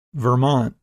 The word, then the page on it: Vermont: English